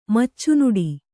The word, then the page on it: ಮಚ್ಚುನುಡಿ: Kannada